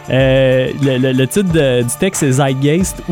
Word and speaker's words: Zeitgeist: Ben, la vraie prononciation en allemand, ça serait Zeitgeist. Faut mettre un T en avant du Z. – Zeitgeist.